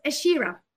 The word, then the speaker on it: Asherah: And her name was Asherah. A-S-H-E-R-A-H. And maybe you can pronounce it the American way. – I mean, I would have said Asherah.